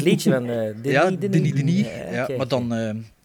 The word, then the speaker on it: Denis: Ja, Enemy van Denis Villeneuve. Ik heb gisteren ontdekt dat het uitgesproken is als, als Denis en niet... Denis.